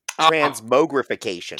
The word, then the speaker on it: transmogrification: No, you did not. Transmogrification. – Transmogrification.